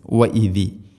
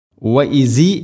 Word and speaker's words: إذ: വ ഇതി വ ഇതിസ്തസ്ക വ ഇസി എന്നല്ല വ ഇതി വ ഇതിസ്തസ്ക ഇസ്തസ്ക ഖാഫ് കനപ്പിച്ചുച്ചരിക്കേണ്ട അക്ഷരമാണ്.